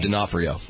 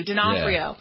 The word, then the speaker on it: D'Onofrio: D'Onofrio. – Thank you. D'Onofrio.